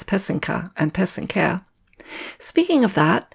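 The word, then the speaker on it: pysanka: I mentioned in episode 1 that pesenka is the singular for Ukrainian eggs using the wax-resist method to write traditional folk designs.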